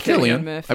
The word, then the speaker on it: Cillian Murphy: I mean, I love, I'm having a brain fart right now on how to pronounce his name, because I know it's Cillian Murphy or Cillian Murphy? – It's Cillian Murphy, I believe.